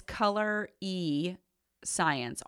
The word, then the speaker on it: Colorescience: Okay, so I'm a big, big fan of, I'm sure I'm going to say the name wrong, Color Science. It's Color E Science, all one word.